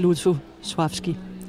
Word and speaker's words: Lutosławski: Ja, altså de fleste her om på bagscenen siger faktisk Lutosławski, har jeg lagt mærke til. Men altså når jeg er i selskab med polakker, så siger jeg det i hvert fald ikke. Fordi der hedder det Lutosławski. – Der er den der lille streg over det andet L, som betyder, at man skal udtale det mere som V.